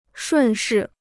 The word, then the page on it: 顺势: shùn shì